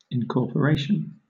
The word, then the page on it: incorporation: British English